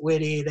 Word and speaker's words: Witte: Some of their big sellers are, there's a beer called Witte, which is spelled the same as my last name. I pronounce it Witte. They call it Witte, but it's a Belgian wit beer.